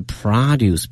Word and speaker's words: produce: 注意一下这个词，农产品是 produce， P-R-O-D-U-C-E。那么如果指一个工业产品的话呢，有另外一个词叫 product， products， P-R-O-D-E-C-T。同时这个发音呢是 products produce，它的发音大家一定要念准了。 – 不光是每一个音节的那个元音要发对，它的每个词里面的每个音节的重音也很重要。